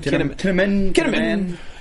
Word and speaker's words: Kinnaman: Kinnaman.